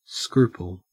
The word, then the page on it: scruple: Australian English